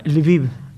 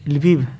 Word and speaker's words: Lviv: Porque acá dice Lviv National Philharmonic. – No, se pronuncia Lviv. Lviv. Se escribe L-V corta, pero Lviv.